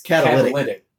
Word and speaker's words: catalytic: On this episode of Brandon Learned Something, is it Cadillac converter? – It's catalytic.